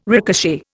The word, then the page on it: ricochet: Indian